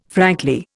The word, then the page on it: frankly: Indian